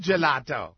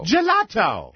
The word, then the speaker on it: Gelato: Gelato. – Gelato. – It rhymes with one of Rob's favorite words.